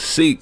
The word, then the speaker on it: Sikh: It's Sikh, my nigga. – Like I gotta say, like S-E-E-K. – It's S-I-K-H. It's, yeah, but I Sikh. Say it like Sikh.